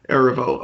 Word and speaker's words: Erivo: Erivo.